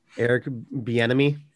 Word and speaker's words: Bieniemy: Eric Bieniemy.